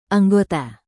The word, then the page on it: anggota: indonesian